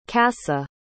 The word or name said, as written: kassa